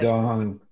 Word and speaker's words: Qigong: Is it Qi? I could never pronounce it correctly. – Qigong. Qi. – Qigong. Qigong. – Yeah. – we can't even pronounce it, but it's C-H-I.